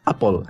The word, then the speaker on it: Apple: Vino Alfalta 90 y nos dijo: Es Apple. Aquí en, América decimos Apple, así que Apple.